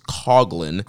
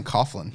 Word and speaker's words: Coughlin: So we had Carl Fredericks defeating Alex Coglin, and yes, his name is pronounced Coglin. I've been saying Coughlin. Yeah, we've everybody I've listened to has been Isn't saying. that how you pronounce GH cough? – That's what I thought, but apparently everybody's been saying it wrong. It is Coglin, not Coughlin.